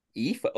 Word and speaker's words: Aoife: You mentioned you didn't know how to pronounce, I believe you spelled it A-O-I-F-E. – That's what it said on the card. – Yeah, it's pronounced Aoife. – Aoife?